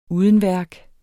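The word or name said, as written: UDENVÆRK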